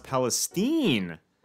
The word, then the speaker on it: Palestine: Oh, it's pronounced Palestine by the residents. Palestine. The more you know.